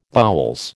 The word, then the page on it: boules: American English